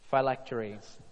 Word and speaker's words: phylacteries: Phylacteries."